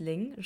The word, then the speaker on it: Linh: Also mein Name ist Ling, schreibt man L-I-N-H, spricht man aber mit G.